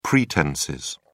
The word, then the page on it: pretences: British English